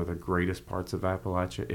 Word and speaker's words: Appalachia: Love his children's book, A is for Appalachia.